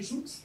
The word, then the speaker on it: Joux: Je ne sais pas si on dit Joux, mais je suis... – Oui, oui. – Je suis du sud-ouest, donc je prononce toutes les lettres. Donc c'est Joux.